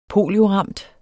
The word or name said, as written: polioramt